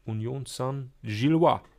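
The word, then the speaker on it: Union Saint-Gilloise: Indem wir fragen, wie das Spiel heute Abend ausgeht zwischen Bayer Leverkusen und Union Saint-Gillois.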